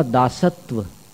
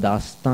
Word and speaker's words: दास्ता: ये इंद्रियों का दासत्व, इंद्रियों की दासता, उर्दू भाषा में कहें अपनी करुण दासता है, दासता दास्ता, थोड़ा फर्क है उच्चारण में। दास्ता माने अपनी करुण कहानी है, अनादिकाल से अपन दास बने रहे हैं, अब स्वामी की शरण में आए हैं।